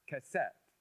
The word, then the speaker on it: qeset: By the way, the word that God uses here for rainbow is the Hebrew word keset. Not cassette as in the ones we used to play in our car. It's spelled Q-E-S-E-T, pronounced cassette.